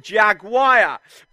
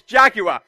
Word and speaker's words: Jaguar: This car was known as the Jaguar or as Americans butcher the English language and call it Jaguar. But actually, it's a British company, British car, so it's Jaguar.